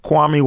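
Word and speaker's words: Quami: Kwame Wallen.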